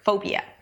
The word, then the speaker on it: phobia: And then it's the word phobia, and that's actually how you pronounce the word, phobia. It's an F sound and then the O as in go. Pho, it's a diphthong, so go through the entire vowel.